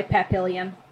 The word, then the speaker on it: Papillion: I'm assuming that's how that's pronounced, because it looks like Papillon. – I mean, it's the Midwest, so it's probably Papillion.